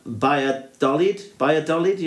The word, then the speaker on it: Valladolid: Yeah, he's from, and now apologies for any Spanish speaking listener, but it looks like they're from Valladolid, you know, as in Real Valladolid. Starts with a V, but it's pronounced B.